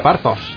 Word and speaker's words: Parthos: Esto se pronuncia Parthos, ¿no? – Yo lo diría Parthos. – Parthos y otras historias. Los Parthos. – Sí. Vale. Con H intercalada, hay que decirlo.